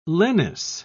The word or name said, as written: lenis